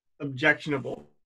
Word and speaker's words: objectionable: Take a, taking a fuck is morally, morally ob, ob, objectional. I can't pronounce this word. Wow. ob, objectionable.